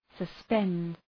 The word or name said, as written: suspend